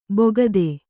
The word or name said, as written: ಬೊಗದೆ